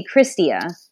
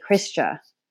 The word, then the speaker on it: Kristia: My mom gave me one name, which is Kristia. The story behind it is that you can say it different ways, and I say it different ways myself. So Americans say Kristia, and Filipinos say Kristia.